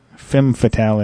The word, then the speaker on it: Femme Fatale: Anyways, Louise, Adorable Doll, Misconduct, Lioness, Jim's Kitten, and the subs for the female for the Femine Fatale. Femme Fatale, that's what I say. I can pronounce that a little bit easier.